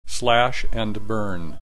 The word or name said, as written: slash and burn